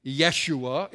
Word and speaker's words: Yeshua: Because the name Jesus was a common enough name, Joshua, Yeshua, it would have been pronounced in the language of the time, but it means God is salvation, God is deliverance.